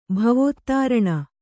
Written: ಭವೋತ್ತಾರಣ

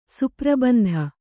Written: ಸುಪ್ರಬಂಧ

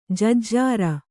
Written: ಜಜ್ಜರ

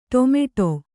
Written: ಟೊಮೆಟೊ

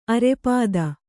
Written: ಅರೆಪಾದ

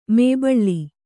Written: ಮೇಬಳ್ಳಿ

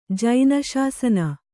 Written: ಜೈನಶಾಸನ

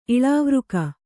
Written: ಇಳಾವೃಕ

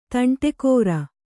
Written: ತಂಟೆಕೋರ

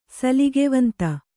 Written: ಸಲ್ಗೆವಂತ

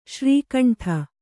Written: ಶ್ರೀಖಂಡ

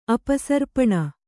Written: ಅಪಸರ್ಪಣ

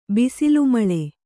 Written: ಬಿಸಿಲುಮಳೆ

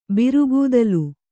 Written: ಬಿರುಗೂದಲು

